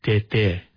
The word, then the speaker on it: Tay-Tay: It would actually be pronounced Tete, right? – Yes, you're yeah.